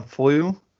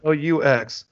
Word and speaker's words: flux: who would pronounce it Aeon Flu as if the X was like silent somehow. – Yeah. – Flux. It's not the flu capacitor. It's the flux capacitor.